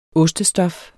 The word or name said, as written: ostestof